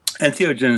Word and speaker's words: entheogen: And maybe we should first introduce Professor Ruck, and thank you for joining us on this episode of Entheogen, as we like to pronounce it. But since you coined the term, could you tell us how to pronounce it? – Entheogen is fine, yes.